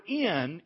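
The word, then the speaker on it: In (ἐν): The word in English, in, here's how it's pronounced in Greek. In. Everybody say that after me. In. Okay, all right, you just learned a Greek word. Now, to transliterate it, it would really be spelled like E-N.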